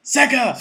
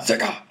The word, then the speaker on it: Sega: He ran across the screen, and with that, some random lunatic that they'd employed to fucking do this went, Sega! – Sega!